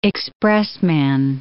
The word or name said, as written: expressman